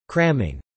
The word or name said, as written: cramming